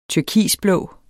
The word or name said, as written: turkisblå